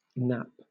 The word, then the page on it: nap: British English